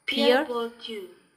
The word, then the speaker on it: Pierre Bourdieu: It is pronounced as Pierre Bourdieu. French.